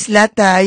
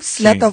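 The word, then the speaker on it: Zlata: Slata Instagram, Slata sí. Oficial en SoundCloud y en YouTube, sí. Y Slata Argentina en TikTok. – Slata, que se escribe con Z, y Lata como lata.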